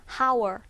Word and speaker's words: hour: 여기에서 이 단어에서 스펠링은 H가 있지만 이 단어의 발음은 H 사운드가 묵음이 나죠. 자, 묵음이라고 하면요. 철자상 보이긴 하지만 소리가 나지 않는 것을 묵음이라고 하잖아요. 그래서 생긴 것은 hour. hour처럼 생겼지만 이 단어의 발음은 hour. hour가 맞죠.